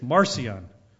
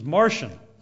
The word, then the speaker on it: Marcion: Some say it's Marcion, but I guess the proper pronunciation is Marcion.